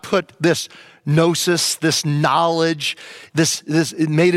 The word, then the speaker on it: gnosis: And Gnosticism comes from the Greek word gnosis, G-N-O-S-I-S, gnosis, the G is silent, gnosis, which means knowledge.